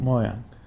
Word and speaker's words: Mojang: Mojang.